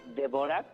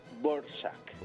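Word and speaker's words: Dvořák: Se escribe Dvorak. – Dvorak. Sí. – Y se pronuncia Borsak.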